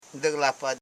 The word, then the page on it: diglapla: di.gla.pla